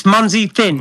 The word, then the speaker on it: Munzee Thin: Well, now I'm nervous every time I'm going to say your name because I'm like, it's not Munzee, it's Munzee. – No, it's Munzee Thin.